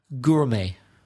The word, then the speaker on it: gourmet: Where's the silent letter in gourmet? – Well, it's the silent T at the end.